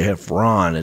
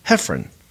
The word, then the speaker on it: Heffron: Some people mispronounce it and say Heffron. It's actually Heffron.